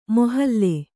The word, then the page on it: ಮೊಹಲ್ಲೆ: mohallae